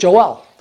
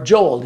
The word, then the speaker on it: Joel: The prophet Joel, or Joel, depending on how you want to pronounce his name, spoke specifically of the last days, what he calls the terrible days of the Lord.